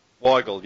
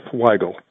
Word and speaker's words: Weigel: I don't have any colorful stories about my name, but you can remember how to pronounce it by the first part being Y, just like the question who, what, when, where, and why. And Gull is just like the end of Seagull. So Jeff Weigel.